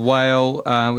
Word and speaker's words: Mhaol: The band are called Whale, which is Gaelic. You can actually spell it M-H-A-O-L if you're searching for that.